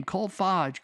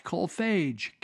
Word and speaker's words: Kolfage: But they're going to put all these guys like Colf, Colfarge or Kolfage. I can't say his name. Kolfage, Kolfage, K O L F A G E.